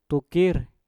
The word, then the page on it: tukir: mpur